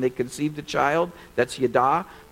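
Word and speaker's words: yada (יָדַע): The Hebrew word that's translated know would be pronounced yada.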